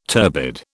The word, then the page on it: turbid: British English